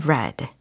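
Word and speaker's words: read: on read라고 읽으면 안 되고 on r e d 라고 과거적으로 해석을 하셔야 되고 과거적으로 읽어 주셔야 됩니다. 어, read라는 철자이지만 발음은 red라는 거 기억해 주세요.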